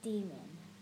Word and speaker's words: daemon: The word daemon is pronounced demon.